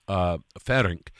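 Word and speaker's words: Farrenc: Ferenc, who lived in... – You pronounce it like Poulenc.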